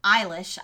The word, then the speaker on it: Eilish: Kevin Hart, LeBron James, Dwayne The Rock Johnson, Billie Ellish, Eilish, I always say her name wrong, and Ariana Grande.